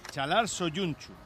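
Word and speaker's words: Çağlar Söyüncü: pero el presentador hoy del acto de bienvenida a Soyunchu lo ha dicho así, Chalar Soyunchu. – Pues entonces será así, evidentemente.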